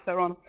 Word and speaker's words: Theron: Und sie hat gemeint, man würde es Thron aussprechen. – Okay. – Das ist ganz witzig. – Ja, auf jeden Fall. – Ich sage auch immer Theron.